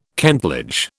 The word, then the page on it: kentledge: British English